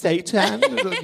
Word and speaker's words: seitan: Yeah, I'm more, tell me more about how you make seitan. And they're like, oh, it's actually pronounced seitan.